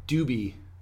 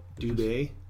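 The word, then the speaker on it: Dubé: Dylan Doobie. That's not how you pronounce his name, is it? – Doobie. I hope so. – Dubay.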